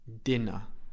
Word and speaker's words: dinner: It's the dog's dinner, dinner, dinner. Again, I don't pronounce the R in British English. I don't say dinner, I say dinner. My mouth is open at the end.